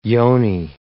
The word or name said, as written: yoni